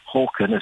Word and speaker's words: Håkan: Whereas suddenly got Harken Karkvist, or Håkan, as it's supposed to be pronounced.